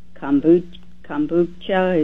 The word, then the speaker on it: kombucha: And I started on the aloe vera gel and kombucha.